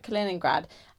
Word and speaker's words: Kaliningrad: Kaliningrad.